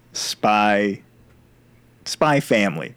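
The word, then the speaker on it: Spy x Family: Man, if you don't watch Spy Family, right? I don't think you pronounce the X, but if you don't watch Spy Family, do yourself the favor.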